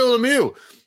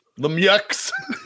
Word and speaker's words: Lemieux: January 12th, in a story Timmy shit on a couple weeks ago, Mary Lemieux of the Pittsburgh Penguins is diagnosed with Hodgkin's lymphoma. – It's pronounced Lemieux. – Lemieux.